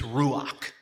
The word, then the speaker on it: ruach: And really it speaks of, as a Hebrew word, it's a cool word, it's ruach. Maybe saying that wrong for some of the ruach. Again, it's got this ruach. Ruach. Thank you.